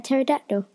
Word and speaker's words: pterodactyl: Yeah, a pterodactyl. – All right. Is that his first name, Terry? All right, Terry. – No, it's... – Oh, mate, I'm Terry Dactyl. – There's actually a silent P.